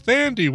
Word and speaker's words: Thandi: I think I said it as Tandy Newton on the show before, and the tadpole erupted with, no, it's pronounced Thandi with a TH.